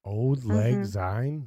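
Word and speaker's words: Auld Lang Syne: Old Lang Syne? – Mm-hmm. That's how it's pronounced.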